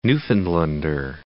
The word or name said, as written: Newfoundlander